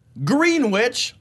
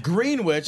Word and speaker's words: Greenwich: Greenwich. I know that's pronounced wrong. – Greenwich? – Greenwich. That's, it's like a sandwich that you leave out for a while.